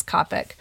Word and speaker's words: Kapic: And I think Anna told me it's pronounced Kopic. I've been saying Kapic. It's Kopic.